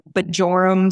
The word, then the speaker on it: Bjorem: So it's B-J-O-R-E-M Speech. It's funny because we get a lot of Bjorem Speech, but it's actually Bjorem.